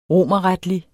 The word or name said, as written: romerretlig